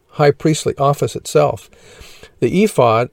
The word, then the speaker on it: ephod: It consisted of the following items: ephod, the ephod pronounced ephod in Hebrew, was an article of sacred clothing worn by the high priests of the Levitical priesthood.